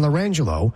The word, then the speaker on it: Orange Jello: And she had a set of twins, and they were the names were pronounced LaMangelo and LaRangelo, but they were spelled Lime Jello and Orange Jello. L-I-M-E-J-E-L-L-O, LaMangelo.